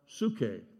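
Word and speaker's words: psuche (ψυχή): There's another word that is pronounced psuche, P-S, for those of you who are taking notes, P-S something. Anyway, and that's the word from which we get our word psychology.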